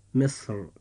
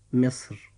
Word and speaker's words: مصر: فتنطق هذه الكلمة في حالة التفخيم مصر، أما في حالة الترقيق فتنطق مصر.